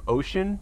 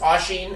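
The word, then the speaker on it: Oisín: It must have been Ireland you were thinking of with the Irish jigs that you did with, I think that they pronounce this name Ocean McCann, maybe? – I think it's Oisin.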